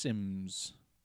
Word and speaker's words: Symes: It's S-Y-M-E-S, but it's pronounced Sims.